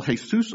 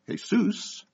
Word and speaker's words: Jesus: They started to call Jesus Alou, J. Alou. Because although his name in Spanish is pronounced Jesus, it's spelled J-E-S-U-S. And as we know in English, that's pronounced Jesus.